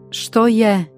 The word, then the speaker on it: što je: S-Apostroph wird wie das deutsche SCH ausgesprochen. Ein häufig gebrauchter Satz ist Steue, das übersetzt was ist bedeutet. Nochmal. – Steue. – Was ist? – Što je?